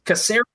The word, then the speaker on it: Caceres: I always mispronounce his last name. – Caceres. – Isn't it? – Yeah, soft C.